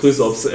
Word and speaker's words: Chrysops: Das wird ja durch so eine äh, Chirops, spricht man, oder Chirsops? Oder Chrysops. Chrysops, ja, ich denke.